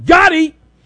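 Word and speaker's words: GOTI: It's G-O-T-I, which I think is pronounced Gotti, right?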